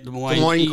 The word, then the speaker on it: Des Moines: Des Moines, is that how you say it? – Des Moines.